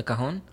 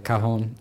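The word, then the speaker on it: cajón: In Japan, I did a little bit with my drummer playing the, how would you say it in English, cajon? – The cajon? – Yeah, the cajon.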